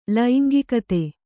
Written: ಲೈಂಗಿಕತೆ

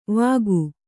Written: ವಾಕು

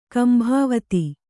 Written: ಕಂಬಾವತಿ

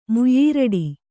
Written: ಮುಯ್ಯೀರಡಿ

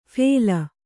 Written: ಫೇಲ

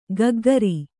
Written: ಕಕ್ಕುಗುರು

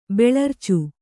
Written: ಬೆಳರ್ಚು

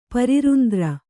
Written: ಪರಿರುಂದ್ರ

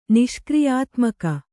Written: ನಿಷ್ಕ್ರಿಯಾತ್ಮಕ

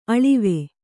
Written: ಅಳ್ವು

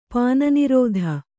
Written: ಪಾನನಿರೋಧ

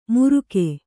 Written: ಮುರುಕೆ

